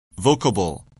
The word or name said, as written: vocable